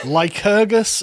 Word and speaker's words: Lycurgus: you pronounce it Lycurgus of Sparta. – Lycurgus of Sparta, which I'm sure, as with all of your discussions with Bo, is very interesting.